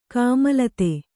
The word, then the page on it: ಕೋಮಲತೆ: kōmalate